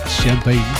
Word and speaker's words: Champagne: Ja, zo spreek je het uit. Het kan ook campagne zijn, maar het is toch echt Champagne.